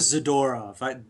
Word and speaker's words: Zadorov: I go with Zadorov. I think we like pronouncing the second to last syllable with the stress.